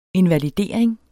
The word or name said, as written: invalidering